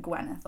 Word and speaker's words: Gwyneth: Unless you, I guess, are from Wales, which is where the name is from. – Right. – So, yeah, because even here people really pronounce it Gwyneth a lot right.